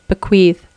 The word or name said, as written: bequeathe